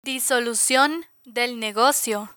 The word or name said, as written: disolucion del negocio